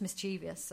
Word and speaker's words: mischievous: It's a bit more than just mischievous, I think. – Yeah, I think there he's doing it in a mischievous... How do you say it? Mischievous. – Yeah, either one. It's fine.